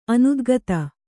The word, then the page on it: ಅನುದ್ಗತ: anudgata